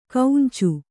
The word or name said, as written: ಕೌಂಚು